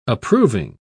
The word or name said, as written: approving